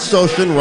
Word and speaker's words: Socian: So Dan Socian rhymes with ocean, right?